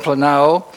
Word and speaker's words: planao: It's phonetically spelled P-L-A-N-A-O, planao, and with a long A, planao, deceive.